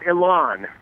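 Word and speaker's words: Élan: Your album, is it Elan? Is that how you pronounce that? – It's called Elan.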